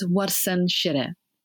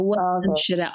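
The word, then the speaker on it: Warsan Shire: In Somali, the way we'd pronounce it is Warsan Shiro. So Warsan Shiro.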